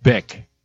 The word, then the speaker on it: Bick: Violet Bick. B-I-C-K. – Rhymes with? Chick.